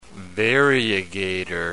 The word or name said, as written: variegator